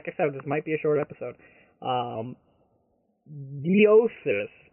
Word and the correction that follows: Noesis: but I was told that's not how you pronounce it. Nick said it sounds like thesis, only without the TH, so ESIS. Is that how you pronounce it, Nick? – Noesis.